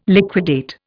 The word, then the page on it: liquidate: Indian